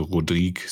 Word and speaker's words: Rodrigues: Also Rodrigue sagt man, was äh, ja auch genau.